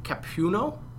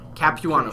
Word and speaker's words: Capuano: And tell me if I'm pronouncing it wrong, saying Mike Capuano? – Capuano, excuse me.